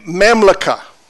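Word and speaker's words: mamlaka (ממלכה): That word dominion in the Hebrew is the word mamleka. mamlaka. It's hard to pronounce. I don't do good with those words. It's mamlaka, which means this it means to rule, it means to reign, and to master.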